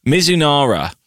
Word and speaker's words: Mizunara: Yeah, so this is the Mizunara.